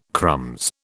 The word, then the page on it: crumbs: British English